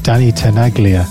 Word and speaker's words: Danny Tenaglia: Danny Tenaglia.